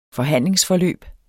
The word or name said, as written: forhandlingsforløb